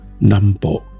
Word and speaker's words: Nambo: 由台湾的机构跟人员所提供的宝贵技术和经验，关键形塑了日本战前在华南的非正式帝国，以及对所谓 Nambo，那这是日语的发音，南方指的是华南、东南亚还有南太平洋这块区域进行军事占领。